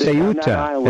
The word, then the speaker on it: Ceuta: The fact of the matter is, you have people in Spain, in Cueto, right? In that country right there. Where you have the Ceuta. It's supposed to be pronounced Ceuta. I read that. Otherwise, I wouldn't know. Ceuta.